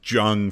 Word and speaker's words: Jung: So, you know, Jung is probably, and that's Jung for you, if you're looking at how it's spelled.